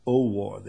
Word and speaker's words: Owuor: Dr. David Owor, O-W-U-O-R, Owor, they pronounce it.